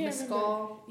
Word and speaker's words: Mescal: Mescal? – Yeah.